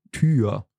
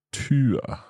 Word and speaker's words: Tür: And again, you have words that it's pronounced a little bit longer. For example, Tür. – Tür. – Genau, the door. – The door, yeah. – Tür.